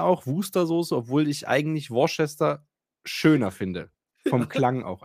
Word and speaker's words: Worcester: Aber es wird anders ausgesprochen, als es im Endeffekt auf dem Ortsschild steht, nämlich Richtig. Wooster.